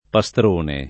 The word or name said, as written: Pastrone